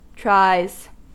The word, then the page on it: tries: American English